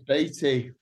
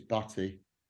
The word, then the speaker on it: Baty: Yeah, we had a courier come around who pronounced my surname correctly, and it doesn't happen often. Usually it's Batty.